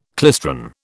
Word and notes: klystron: British English

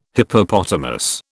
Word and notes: Hippopotamus: British English

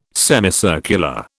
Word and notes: semicircular: British English

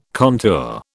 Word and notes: contour: British English

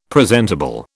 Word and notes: presentable: British English

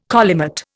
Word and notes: collimate: Indian

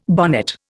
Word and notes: bonnet: Indian

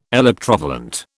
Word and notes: electrovalent: British English

